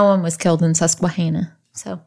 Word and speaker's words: Susquehanna: Susquehanna.